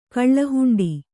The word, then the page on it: ಕಳ್ಳಹುಂಡಿ: kannada